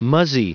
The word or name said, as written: muzzy